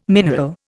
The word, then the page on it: mineral: Indian